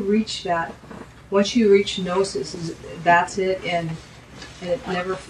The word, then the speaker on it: gnosis: Kenosis is spelled in English with a K, K-E-N-O-S-I-S. Gnosis is actually spelled with a G, but you don't pronounce the G, G-N-O-S-I-S.